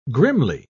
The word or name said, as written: grimly